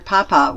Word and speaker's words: papa: In Spanish, you emphasize the second syllable in papa, she explained during a break in rehearsals. However, I have to remember my character lives in Paris, and the French pronounce papa without any special emphasis.